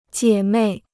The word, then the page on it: 姐妹: jiě mèi